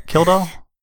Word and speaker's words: Kildall: I looked at it briefly, but it was mostly a documentary about Gary Kleidahl. So Kildahl? – Kildahl, yes, sorry.